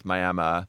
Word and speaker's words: Miami: Yeah, it's spelled Miami, pronounced Miami, and it's a small town in the panhandle of Texas.